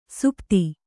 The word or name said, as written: ಸುಪತಿ